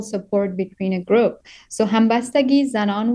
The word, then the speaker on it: Hambastagi: It's called Hambastagi Zanon. Am I pronouncing that correctly? – Yes. Yeah.